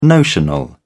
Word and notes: notional: British English

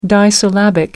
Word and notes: disyllabic: American English

